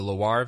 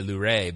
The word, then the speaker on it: Loire: the Loire Valley. I always want to say Luray, but I think it's Loire.